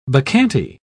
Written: bacchante

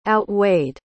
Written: outweighed

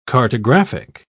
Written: cartographic

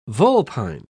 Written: vulpine

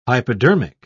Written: hypodermic

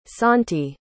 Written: santi

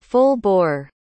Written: full-bore

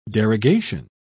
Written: derogation

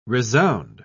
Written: resound

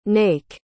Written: naik

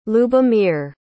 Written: lyubomyr

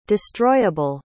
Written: destroyable

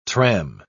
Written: tram

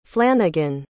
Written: flanagan

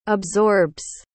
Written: absorbs

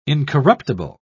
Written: incorruptible

